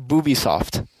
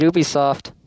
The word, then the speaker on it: Ubisoft: I swear to God, I heard Boobisoft. – I said Ubisoft. – Would that be spelled B-U-B-I, or...